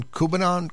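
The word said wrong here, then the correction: Khudobin: Hudobin. – Hudobin.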